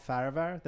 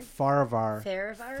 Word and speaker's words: Farahvar: My name is Paul Farahvar. That's the way you're supposed to say it. But Farahvar? I've been saying Farahvar for 27 years.